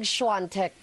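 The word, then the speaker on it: Świątek: Ba't ang basa, yung isa ko narinig, Siantec? – Na Siantec. – Siantec. – Siantec. – Pero yung pagkaka-spell niya, Siantec. – Diba? Siantec. Pero Siantec. – Siantec. – Siantec ang pronunciation.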